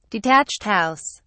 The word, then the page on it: detached house: British English